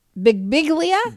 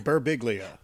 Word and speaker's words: Birbiglia: Bigbiglia? – Birbiglia.